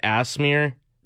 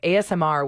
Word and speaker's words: ASMR: Can you tell me again what the hype is all about with Asmir? – What? – That's not how you say it. It's ASMR, which stands for Autonomous Sensory Meridian Response, which is that feeling, static-like, tingling, goosebump sensation response to some sort of audio or visual stimulation.